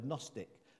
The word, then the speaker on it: Gnostic: It has a silent G on the word, Gnostic.